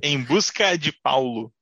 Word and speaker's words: Em Busca de Paulo: The title is Embusco de Paulo. – Ah. O título é Em Busca de Paulo. – Did I say that right? – Yes, you did perfectly. – Okay. – Em Busca de Paulo.